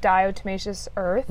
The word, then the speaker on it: Diatomaceous Earth: And people did recommend Diatomaceous Earth mm-hmm.